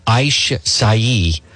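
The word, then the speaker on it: açaí: It is pronounced Aishai, a little purple fruit, but all you need is an inch.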